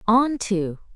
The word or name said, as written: onto